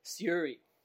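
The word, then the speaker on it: Syuri: It's S-Y-U-R-I. – Siuri.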